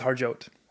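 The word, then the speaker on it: Harjote: It's Harjot.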